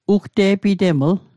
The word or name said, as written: ugte'piteml